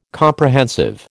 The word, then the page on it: Comprehensive: American English